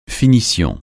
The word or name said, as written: finition